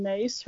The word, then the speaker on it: Myss: Well, I'm a fan of Caroline Mace. Her last name is spelled M-Y-S-S.